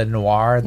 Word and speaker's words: Noir: Too is I don't know how to say this word. It's spelled N-O-I-R. The Noir. Noir.